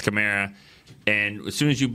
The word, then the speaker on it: Kamara: But the scary part about that to me is when you blitz and you think that I want to say Sproles. When you say that Kamara. I just read the pronunciation. – It is? – It's Kamara.